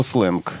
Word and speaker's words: сленг: Кстати, как пишется слово «сленг»? – Сленг пишется через «е», хотя произносится твердо, и это, в общем, нормально для русской графики.